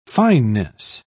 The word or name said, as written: fineness